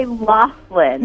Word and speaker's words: Loughlin: So, I have a couple of really important questions, but the first one is it Lori Loughlin or Lori Loughlin? – How do you pronounce it? – I say Loughlin.